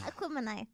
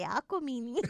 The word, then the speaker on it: Aquemini: Aquemini. I was about to say Aquamini.